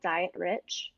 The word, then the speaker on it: Dietrich: Dietrich is spelled diet rich.